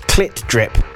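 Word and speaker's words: CLT DRP: Because it's spelt with no vowels, but it's apparently pronounced clit drip.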